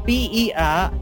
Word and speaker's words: Berlinetta: Is the car driven in Outrun, a Ferrari Berlinetti? Berlanti. – It would help if you could spell it. Pronounce it. – shall I say it? It's spelled with Berlinetta.